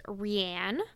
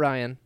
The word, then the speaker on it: Rianne: So the first Gelfling that we get to know is Rianne. – Yep, not Ryan. – Not Ryan, even though it's spelled like Ryan.